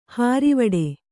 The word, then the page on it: ಹಾರಿವಡೆ: Kannada